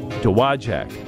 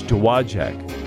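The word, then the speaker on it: Dowagiac: he gave me a pronouncer. D-O-W-W-A-J-A-C-K. Now say it fast. Dowajak. He says Dowajak. Dowajak, Michigan.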